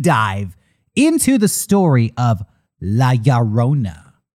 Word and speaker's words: La Llorona: How pronounce it again. – La. – La. – Ya. – Ya. – Ro. – Ro. – Na. – La Llorona. – You said it perfectly.